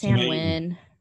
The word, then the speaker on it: Samhain: Well, seeing, like, I'd always read it as Samhain. – I Googled it. It's Samhain. – Samhain. – But it is spelled Samhain. – Right. – It's that Celtic holiday that we don't pronounce after those letters.